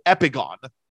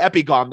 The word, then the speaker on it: Epigone: And the big difference between Veil of Imagination and Epigone, which I would have bet my life that you pronounced it Epigone, but I was going to say exactly the same thing. – In Buke's interview, Evan calls it Epigone. So if he pronounces it Epigone, that's how it's pronounced.